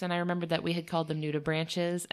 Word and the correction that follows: nudibranchs: and I felt really self-conscious about it. So just to double-check myself, I messaged the Monterey Bay Aquarium and asked them how they pronounce it, and they said nudibranchs, so I'm going to take their word for it and apologize for mispronouncing nudibranch over and over again.